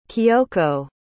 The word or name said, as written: kioko